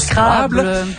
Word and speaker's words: Scrabble: On va en parler avec Pascal Binet, les maires d'Adstock, et on termine l'émission aujourd'hui en parlant du bon vieux Scrabble, ou prononcé correctement en français, le Scrabble.